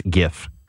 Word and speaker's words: GIF: So someone had the first words, G stands for graphic. Graphic has a hard G. It's GIF.